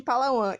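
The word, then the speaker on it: Palawan: Na província de Palauan Isso eu tenho certeza que é assim mesmo que se, que se pronuncia após vencer um lance de moedas do melhor de três.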